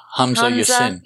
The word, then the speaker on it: Hamza Yassin: Hamza Yassin.